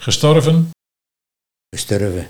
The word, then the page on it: Gesturven: Nieuw lekkerlands